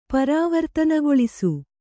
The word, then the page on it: ಪರಿವರ್ತನೆಗೊಳಿಸು: pari vartanegoḷisu